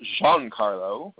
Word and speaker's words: Giancarlo: And Giancarlo, because his name is actually pronounced Giancarlo, as we found out at Comic-Con.